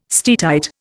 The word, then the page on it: steatite: Indian